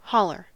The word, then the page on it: holler: American English